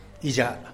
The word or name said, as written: izhaa